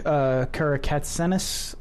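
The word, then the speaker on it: Karakatsanis: It was from Alec Karakatsenis, I assume that's how you pronounce that name.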